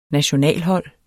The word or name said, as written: nationalhold